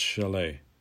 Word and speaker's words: de Chelly: Pueblo Indians settled on lands at the Canyon de Chelly, which is pronounced Chalet, then the northeastern corner of Arizona, and built cliff dwellings high in the sandstone alcoves.